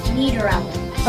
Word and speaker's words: Niederauer: Niederauer.